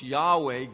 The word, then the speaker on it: Yahweh: Lord being in all caps, the word we believe is pronounced Yahweh, God's name.